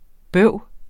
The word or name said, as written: bøv